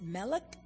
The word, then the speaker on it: melk: It's melk, pronounced melk, but it's milk.